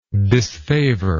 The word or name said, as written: disfavorer